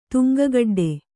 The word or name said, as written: ತುಂಗಗಡ್ಡೆ